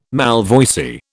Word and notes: malvoisie: British English

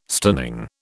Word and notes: stunning: British English

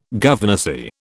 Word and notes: governessy: British English

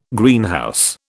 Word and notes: Greenhouse: British English

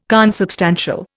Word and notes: consubstantial: Indian

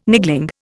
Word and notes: niggling: Indian

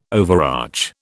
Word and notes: overarch: British English

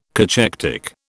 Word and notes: cachectic: British English

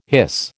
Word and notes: hiss: American English

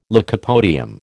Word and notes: lycopodium: American English

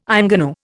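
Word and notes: ingénue: Indian

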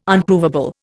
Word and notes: Unprovable: Indian

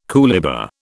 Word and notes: coolibah: British English

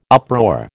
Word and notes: uproar: American English

